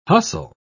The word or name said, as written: hustle